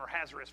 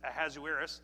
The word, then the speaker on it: Ahasuerus: The Bible says, Esther chapter number 1, now it came to pass in the days of Ahasuerus, that this is, I've been calling him Ahasuerus for a long time. I learned you pronounce it Ahasuerus, which reigned from India even unto Ethiopia over 127 provinces.